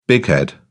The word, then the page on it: bighead: British English